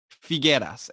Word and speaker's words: Figueras: That would be Fernando Figueras, and that is spelled F-I. G-U-E-R-A-S Figueras.